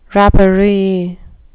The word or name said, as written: rapparee